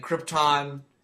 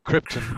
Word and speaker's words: Krypton: For me, you really can't beat the first half hour of Superman with the origin and Krypton, him getting to Earth. – Please pronounce it correctly. Pronounce it like Marlon Brando. Krypton.